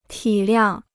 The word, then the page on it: 体谅: tǐ liàng